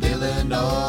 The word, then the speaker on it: Illinois: It ends with an S, but don't say it, boy, when mentioning Springfield, Illinois.